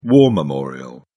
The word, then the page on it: war memorial: British English